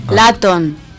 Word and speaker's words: Luton: Laton. – Laton, ¿ves? – Joder. – Es que no sabéis pronunciar. Yo digo Luton. – Pues es Laton.